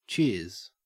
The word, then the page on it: cheers: Australian English